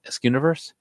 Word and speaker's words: Askewniverse: Askewniverse? – Yeah.